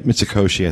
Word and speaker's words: Mitsukoshi: Is that how you pronounce it? – Yeah, Mitsukoshi, I think it is.